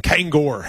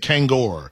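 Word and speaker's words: Kangol: Kangor hats. – Kango. Yeah, he's in. – Did I say it wrong? – Kango hats. You said Kangor.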